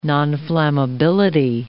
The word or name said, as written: nonflammability